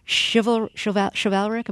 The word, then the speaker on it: chivalric: You say the relationship was chivalric, am I saying that right? – Yep.